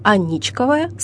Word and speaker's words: Аничков: «Аничков – мост», «Аничков – дворец», «Аничковая – слобода». Ударение падает на второй слог – «Аничков».